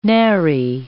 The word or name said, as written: nary